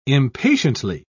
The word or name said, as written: impatiently